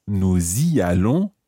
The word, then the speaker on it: nous y allons: And notice that we pronounce the S of nous y allons. Okay, the nous has the S pronounced here because it's followed by a vowel sound.